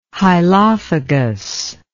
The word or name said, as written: hylophagous